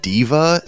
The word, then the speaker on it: Daiva: He worked on Daiva, which is like it's spelled Daiva, but it's like in Japanese, like phonetically it's Diva, so I'm not exactly sure there, but it's Diva Story, which is like the it's like an war simulation.